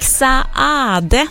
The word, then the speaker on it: Saade: Erik Saade, så har jag lärt mig att man ska uttala hans namn. Han stavar ju S-A-A-D-E, och då ska man tydligen uttala båda A-en också, så då gör jag det.